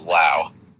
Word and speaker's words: Lowe: They have the presumptive rookie of the year in Brandon Lowe, or Lau. I think he wants to be called Lau, although it's spelled the same as Nate Lowe.